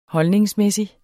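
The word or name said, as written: holdningsmæssig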